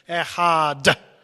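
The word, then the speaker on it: Echad (אחד): And that word Echad, in fact we say it with a special pronunciation, Echad. Because if we said Echer, Acher, it would mean another God.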